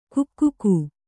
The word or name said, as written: ಕಗ್ಗ